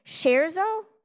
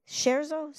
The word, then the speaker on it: scherzo: Kind of catch our breath, regroup, because what comes in July, August, and September, and I'm going to pronounce this wrong, scherzo? Is that how you pronounce it, Seth? Scherzo? Sure, I think so.